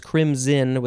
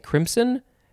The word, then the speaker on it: crimson: And it does say that the pronunciation is crimson with a Z sound, but I guess you could maybe also say crimson. But that sounds a little bit weird.